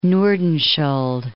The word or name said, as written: Nordenskiold